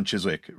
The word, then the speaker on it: Chiswick: I always mispronounce it as Chiswick, but Chiswick stuff.